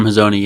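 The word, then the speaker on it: Hazony: Yoram Hazzoni, yeah.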